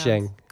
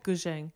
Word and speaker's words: Guzheng: never pronounce, Guzheng. Guzheng.